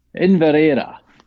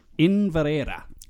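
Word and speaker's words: Inveraray: Okay, what's the correct pronunciation? – Inverera. – Inverera. – Inverera.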